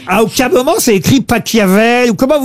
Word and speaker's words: Pachelbel: Ça s'écrit P-A-C-H-E-L-B-E-L. – Mais il adorait l'Italie. – Mais par contre, Laurent, s'il est allemand. – Cannelloni, c'est Pachelbel. – S'il est allemand, ça se dit Pachelbel.